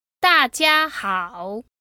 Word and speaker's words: 大家好: 大家好。 – Again? – 大家好。